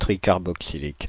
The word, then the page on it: tricarboxylique: [tri.kaʁ.bo.ksi.lik]; Paris